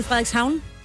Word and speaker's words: Frederikshavn: Jeg har været i Frederikshavn.